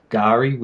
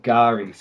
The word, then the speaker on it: K'gari: the indigenous name, which is Gari, which is spelled K-G-A-R-I. – Can you say that one more time? – Yeah, it's called Gari, so K-G-A-R-I.